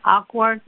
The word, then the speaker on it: Acworth: I think that's how you say it, Ackworth, A-C-W-O-R-T-H.